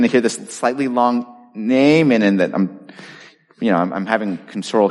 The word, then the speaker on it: Naaman: His name is spelled N-A-A-M-A-N, so when I used to read these passages to my kids, I'd say, Naaman. I just got to say that once to get it out of my system.